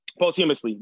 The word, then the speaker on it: posthumously: Uh, but we heard Legends Never Die postmously, however you pronounce that. Um, and I really did like that album. Like Legends Never Die had a lot of good songs on it. – If you haven't heard it, posthumously, there we go.